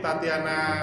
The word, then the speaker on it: Tatjana: Tatiana kayaknya aku curiga bacanya tuh. – Nah, bentar. Wow, ternyata benar. – Ya benar. Jadi Tatiana ta, Tatiana Sapira artis ya artis.